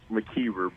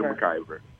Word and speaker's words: Mckiver: It is actually pronounced Mckiver, but I'm used to the Mckiver.